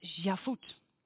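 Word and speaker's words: Shavuot: En eh, in het eh, Joods spreek je dat uit als Javut het feest dat gevierd wordt zeven weken na Pesach, waarbij de gerstoogst wordt gevierd.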